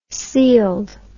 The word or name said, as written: sealed